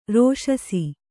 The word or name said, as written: ರೋಷಸಿ